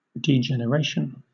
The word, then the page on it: degeneration: British English